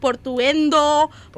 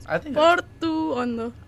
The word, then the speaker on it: Portuondo: Like, they say, like, Portuendo, Portondo. Like, I was like, just follow the pronunciation. Portuondo.